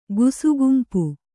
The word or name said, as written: ಗುಸುಗುಂಪು